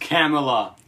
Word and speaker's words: Kamala: It's pronounced Kamala.